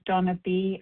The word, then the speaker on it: Dana: Next, we have Donna B., I believe, in Colorado. Thank you, Donna. – Good morning. This is Donna B., calling from Colorado. My name is spelled D-A-N-A, but pronounced Donna.